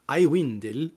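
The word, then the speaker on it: Aiwendil: Ich meine, das ist sowieso einer der geilsten Namen, den man bitteschön nicht auf Deutsch aussprechen muss, sondern immer Iwindel, immer schön Englisch irgendwie und,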